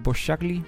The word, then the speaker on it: Boscagli: Dan weet ik alleen niet, zou ik denken Boscagli of Boscagli? Ja. – Boscagli. – Nou, dan gaan we daarop uit. Als we het niet goed uitspreken, dan horen we het graag. Olivier Maxime Boscagli. Dus... Ja, Boscagli. Ah, dat is misschien op zijn Frans, hè?